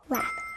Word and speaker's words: wrap: Wrap. – That's right. 포장해요. 포장하다 wrap. 여기 앞에 ruh 소리 들렸죠? 그럼 다시 발음해 주세요. – Ruh, ruh, wrap.